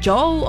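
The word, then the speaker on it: Djo: así que la D es completamente muda. Y si ustedes dicen, oye, se dice DJ O o Joe o cómo se dice, pues simplemente es Joe. Así, como lo oyes.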